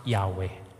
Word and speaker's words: Yahweh: Yahweh.